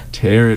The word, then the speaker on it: tarot: Tarot card is correct. – That's how you pronounce that. – What? – Yeah, is it tarot? – Yeah. – Yeah. – That's my bad. My bad. I thought it, I've always said tarot.